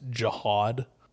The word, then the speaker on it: jihad: Oh, like the one that says jihad should be pronounced jihad? – Yeah, that one.